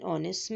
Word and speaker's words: honest: An is also used before a silent H. For example, he is an honest man.